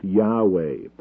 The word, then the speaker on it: Yahweh: This is the tetragrammaton of the Old Testament, sometimes pronounced Yahweh.